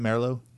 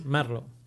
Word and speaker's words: Merlo: Did I say that right, Merlo? – It's yes. Okay. – Merlo. – Merlo, yeah. All right.